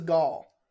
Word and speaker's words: Gal: People still say Gal Gadot. But she says Gal.